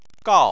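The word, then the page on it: gao: tone 4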